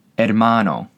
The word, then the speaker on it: hermano: To say the brother in Spanish, you say, el hermano. El hermano. And that is spelled E-L space H-E-R-M-A-N-O. El hermano. Keep in mind that the H at the beginning of the word is silent.